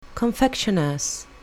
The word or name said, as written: confectioner's